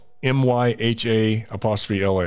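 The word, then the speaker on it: Myha'la: Mahala. I'm trying to, it's MYHA LA.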